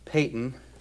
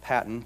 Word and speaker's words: Paton: The 18th century missionary John Paton, sometimes pronounced Patton, John Paton served among the cannibals on the New Hebrides Islands.